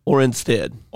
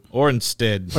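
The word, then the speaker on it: Oranjestad: Orinstead. – Okay. – The J is silent. – Orinstead.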